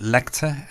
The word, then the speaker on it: LCTR: The next two tracks are by, I guess you pronounce it Lector, L-C-T-R.